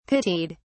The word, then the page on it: pitied: British English